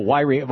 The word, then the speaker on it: Yreka: Oh, Yreka. Well, that's because Paul typed in Whyreek-uh, ca. Thank you, Paul.